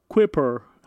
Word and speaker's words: Kuiper: Kuiper, pues bueno, en inglés así, pues. Kuiper. Kuiper. – En inglés debe ser Kuiper.